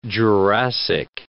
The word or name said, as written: Jurassic